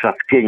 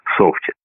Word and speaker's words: софт: В софте ничего фамильярного нет, кроме вот этого ударения, которое я поставил на окончании. Оно свидетельствует тоже об освоенности слова. Я сейчас имитировал, так сказать, жаргон программиста, но я, наверное, скорее бы сказал «софте».